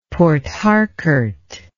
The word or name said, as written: Port Harcourt